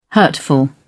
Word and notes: hurtful: British English

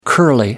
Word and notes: curly: American English

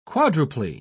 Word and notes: quadruply: British English

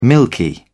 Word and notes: milky: British English

